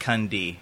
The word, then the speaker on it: Kunde: But in our tongue, we pronounce it Kunde.